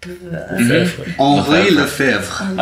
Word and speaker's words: Lefebvre: Lefebvre is much more harder to say. – Okay. – Yeah. Because, yeah, like, the word, right, like, the actual letters in the word and how you pronounce it have no, like, relation.